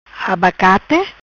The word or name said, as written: Abacate